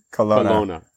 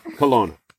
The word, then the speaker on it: Kelowna: I think it's Corona. – Yeah, it's like, yeah, exactly. – Did I say that right? – Kelowna. – Oh, yeah, outside of Vancouver. Kelowna.